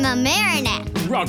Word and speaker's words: Mamaroneck: Oh, Mamaroneck? – No, Mamaroneck. – No, I think it's Mamaroneck. She's no. – From... – It's from Mamaroneck.